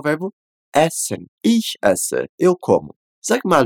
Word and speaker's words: ich: O primeiro do ich, ele é bem contido, aquele CH fraquinho, que a gente faz só com o movimento da língua.